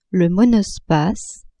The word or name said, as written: monospace